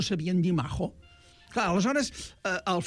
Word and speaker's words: majo: Maco és majo. Majo, majo. – Però mal pronunciat. Va entrar al català en l'època dels meus avis, dels vostres besavis, en què els catalans pràcticament no sabien castellà i no sabien pronunciar la jota castellana.